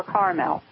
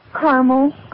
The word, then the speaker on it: Carmel: Do you pronounce it Carmel or Carmel? – Carmel.